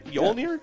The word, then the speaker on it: Mjolnir: I pronouncing that right? Mjolnir? – Yeah, I thought you did.